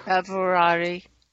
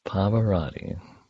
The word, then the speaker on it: Pavarotti: It's like, you know, the one who sings off key and the one who just blows it away, like Pavarotti. Pavarotti. What's his name? – Pavarotti. – I said it right. Wow, look at that. Pavarotti.